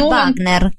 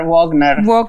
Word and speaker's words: Wagner: Si pronuncia Robert Wagner, chissà. – Ma Wagner. Forse tu l'hai fatta più alla tedesca, ma va bene lo stesso.